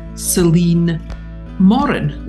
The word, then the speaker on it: Celynn Morin: And today, my guest is Celynn Morin.